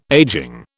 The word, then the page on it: ageing: British English